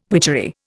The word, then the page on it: witchery: Indian